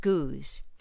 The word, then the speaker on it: Goudge: Her name is spelled G O U D G E, Elizabeth Goudge, but her name in Britain is actually pronounced Goose.